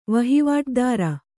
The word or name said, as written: ವಹಿವಾಟುದಾರ